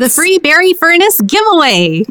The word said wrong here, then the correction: Freebuary: No, it's Freebuary, guys. – Freebuary Furnace Giveaway. – Well, I see we need to work on pronouncing it. Freebuary.